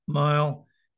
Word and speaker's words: Miel: So it's Mile, M-I-E-L. and it's pronounced Mile, and it's 406 6th Street in New Orleans.